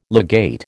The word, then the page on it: legate: American English